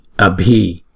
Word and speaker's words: abhi: Chữ này là chúng ta đọc là bhi, bhi, abhi.